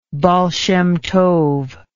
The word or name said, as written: baal shem tov